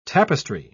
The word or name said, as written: tapestry